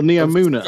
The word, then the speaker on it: Neomuna: The Cloud Striders were from Neomunna. No, Neomuna.